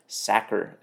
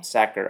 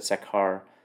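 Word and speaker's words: Sachar: Lewis Sacker is how you pronounce it. – Okay. – Sacker. I always said Sakhar.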